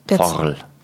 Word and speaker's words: FORL: F-O-R-L.